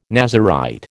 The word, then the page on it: Nazirite: American English